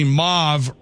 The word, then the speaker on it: mauve: The word M-A-U-V-E is pronounced mauve, rhyming with stove in British English, and more commonly mauve, rhyming with the first part of awful in American English.